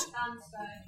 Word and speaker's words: Bansko: Bansko. – Oh, Bansko. There you go, I said it wrong.